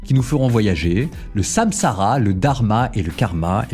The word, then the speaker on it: samsara: On appelle ça le samsara, comme ça se prononce, S-A-M-S-A-R-A.